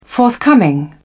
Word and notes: forthcoming: British English

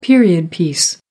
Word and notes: period piece: American English

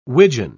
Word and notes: widgeon: British English

